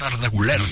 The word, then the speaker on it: Arda Güler: De lo poquito bueno que tuvo el Real Madrid esta temporada fue Arda Güler, como se debe pronunciar, Arda Güler.